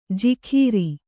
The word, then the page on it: ಜಿಖೀರಿ: kannada